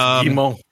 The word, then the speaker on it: Guillemot: It's Guillemot. – I never say it right, so this is all. – You don't pronounce the L's when it's two L's. Like, frog in French is grenouille, but there's two L's. – Oh, well. – O-U-I-L-L-E. So you go, you don't say the, you say Guillemot.